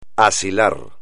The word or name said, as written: asilar